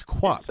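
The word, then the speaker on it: KWAPT: It's quapped. – So quapped. Well, yeah. I don't know. – I was always I was a quapped guy.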